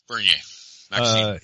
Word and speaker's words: Bernier: Bernier? – Bernier.